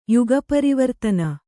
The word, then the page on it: ಯುಗಪರಿವರ್ತನ: kannada